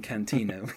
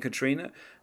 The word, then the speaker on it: Katrina: I I lost it in Hurricane Cantina," which is. I don't even know why she calls it like like I don't know why she mispronounces Hurricane Katrina.